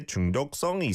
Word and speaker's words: 중독성: And then, 이 노래 중독성 있어.